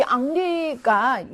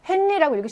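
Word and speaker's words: Henri: 이 앙리가 이렇게 쓰잖아요. 이거 헨리라고 읽으시면 안 됩니다. 이거 영어식으로 읽으시면 안 되고요. 프랑스에서는 아쉬가 발음이 나지 않기 때문에 영어에서의 헨리가 프랑스에서 앙리로 발음이 되는 거죠.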